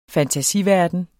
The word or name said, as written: fantasiverden